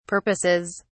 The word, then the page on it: purposes: British English